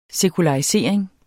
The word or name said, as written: sekularisering